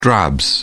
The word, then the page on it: drabs: British English